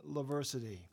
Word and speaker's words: Laversity: Sam Lewis in the WVTF studio for a Roots Down session here, doing some songs from his new album, Laversity. Thanks, Sam, for coming in. I appreciate it. – Thank you for having me, and thank you for correctly pronouncing Laversity. That has been butchered all over the world.